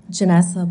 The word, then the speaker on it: Janessa: Janessa Blauvelt, J A N E S S A B L A U V E L T.